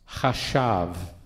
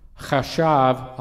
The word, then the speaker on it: chashav: So David is saying, and use the word chashav. Sandra wants me to spell that. C-H-A-S-H-A-V.